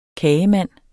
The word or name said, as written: kagemand